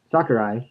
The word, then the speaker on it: Sakurai: But so the playing stand, Sakurai. That's how you say his name, right? – Sakurai.